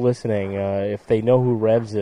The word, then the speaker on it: REVS: And I happen to see all over Midtown Manhattan and Lower Manhattan Who is Rez? – What, Rez? – Who is Rez? – What, is that how it's spelled? – R-E-V-S. – R-E-V-S, Revs.